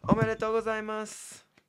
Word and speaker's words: omedetou gozaimasu: That would be omedetou gozaimasu. – What? Omaigato? What? – Sorry. Omedetou gozaimasu.